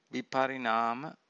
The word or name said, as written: viparinama